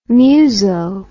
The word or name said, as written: muso